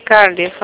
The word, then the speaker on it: Cardiff: Cardes, ¿cómo es? – Se dice Bank of. Y luego Cardiff se escribe con C-A-R-D-I-F-F. – Y Cardiff, okay.